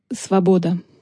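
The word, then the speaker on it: svoboda (свобода): Il se dit « svoboda ».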